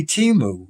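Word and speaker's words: Temu: She said it's simply Timu. – Mm-hmm. – All right. So I thought, well, you know what? Instead of spending $35, let's see if Timu sells that kind of thing.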